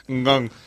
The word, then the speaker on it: Gong: Gong. No se pronuncia nunca la G final. Gong. – Gong, nunca, es Gong.